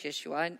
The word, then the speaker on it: Yeshua: So when the Son of God, a man claiming himself to be the Son of God, coming in the name of Yeshua, Jesus in English, Yeshua in original pronunciation, standing before the temple of Jerusalem.